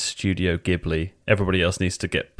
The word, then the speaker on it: Ghibli: So in Japanese, it's Ghibli. – Great. – A lot of people in English say Ghibli, but it is Ghibli.